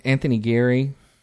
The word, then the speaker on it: Anthony Geary: Anthony Geary.